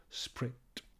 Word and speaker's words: spirit: Note here, by the way, that spirit is pronounced as one syllable, sprit, able sprit affords.